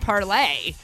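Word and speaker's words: Parler: And I think it's actually pronounced parlay. – Is it? – Yeah, because it's the French version.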